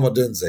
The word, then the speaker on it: Odunze: Um, is it Odun Odunze? How do you pronounce? Yeah, that? Rome Odunze.